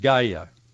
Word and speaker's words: Gaia: It's spelled G-A-I-A. It's pronounced different ways, but mostly people in English pronounce it Gaia.